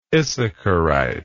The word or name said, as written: issacharite